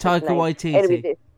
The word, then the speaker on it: Taika Waititi: And I am definitely shipping Rhys Darby and Taika Waititi, I can never pronounce Taika Waititi.